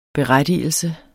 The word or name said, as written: berettigelse